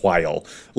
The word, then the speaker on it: while: in a while. Like, and that's while with the H. Like, I am passionate about this. – Emphasis on the H and while.